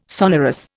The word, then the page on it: sonorous: Indian